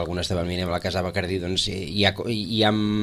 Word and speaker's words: Bacardí: ara el que crida més l'atenció és l'accent tancat a la I de Bacardí. Per tant, ara diguem-ne que el logo ja apela a la pronúncia catalana del nom, aquest Bacardí.